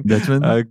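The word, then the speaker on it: Deutschman: Dutchman? – Correct. – Exactly. Exactly like that.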